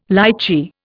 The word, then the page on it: lichee: Indian